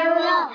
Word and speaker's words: الرحمن: ألف وسط في وسط الكلام لا ينطق لام شمسية مضغمة لا تنطق راء شدة وفتحة مفخمة. – بسم الله الرحمن.